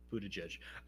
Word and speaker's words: Buttigieg: Yeah, it's mostly the G-I-E-G part. – Isn't it? – I wouldn't have expected both of the G's to be pronounced J's. – Buttigieg.